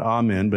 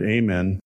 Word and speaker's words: Amen: Daniel Amen, I think is how you pronounce his last name, not Amen, but Amen. Amen is a psychiatrist who some of you have seen on PBS.